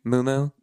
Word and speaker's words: Miu Miu: Is it Miu Miu? Is that how you say it? – I don't. – Moo Moo? Yeah, okay. I got Miu Miu boots for originally $1,100.